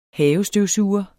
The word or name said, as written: havestøvsuger